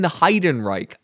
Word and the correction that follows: Ehrenreich: Aiden, am I pronouncing that right? It's from across the table. – It's Ehrenreich. So something however like German you want to go.